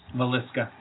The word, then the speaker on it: Villisca: Villisca.